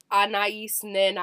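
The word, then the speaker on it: Anaïs Nin: The other mural was Anais Nin. Is that how you say her name? – Oh, Anais Nin.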